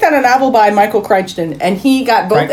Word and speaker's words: Crichton: It's Crichton.